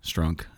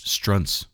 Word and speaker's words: Strunz: After the rehearsal and says, the name is not pronounced Strunk. And I was like, ooh, I should have known that. It's Strunz. Strunz.